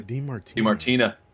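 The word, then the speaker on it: Di Martino: DiMartino.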